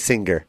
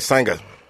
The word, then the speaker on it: singer: He puts the hard G. – Because I don't say singer. – No, well, no, it's because you put a hard G on it.